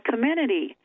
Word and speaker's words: Comirnaty: Comirnaty. That's it. – I think that's Comirnaty. It. I think you've got it. – It's a lot like the women's shopping group that's called Comirnaty.